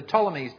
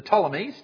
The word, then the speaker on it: Ptolemies: There was the Ptolemies down in Egypt, with a silent P. The Ptolemies down in Egypt, and then there was the Seleucids up in the north around Syria.